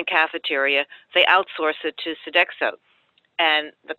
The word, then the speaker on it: Sodexo: So one of the things I wanted to bring up was you also did a big investigation of Sodexo. I think that's how you pronounce their name, the big food company. – Yes. Yeah. – Tell us a little bit about that. – Sodexo is one of the three worldwide food service providers.